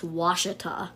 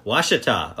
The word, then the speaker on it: Ouachita: It's spelled O-U-A-C-H-I-T-A, but next to it has a pronunciation parentheses, and apparently it's pronounced Washita. – Washita.